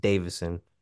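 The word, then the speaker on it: Deiveson: Like, it's spelled really weird, but it's literally just Davison. It's spelled weird, but it said Davison.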